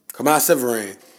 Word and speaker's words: Kemah Siverand: I think it's Kama Sivaran, Sivaran, Sivaran. – Kemah Siverand.